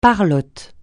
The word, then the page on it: parlote: francais